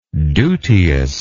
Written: duteousness

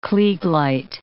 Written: kleig light

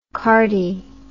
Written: cardy

